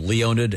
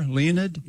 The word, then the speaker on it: Leonid: This band called Leonid and Friends. – Yeah, I'd never heard of them until you brought them up. – Is it like the meteor shower, Leonid? – Yeah, it is.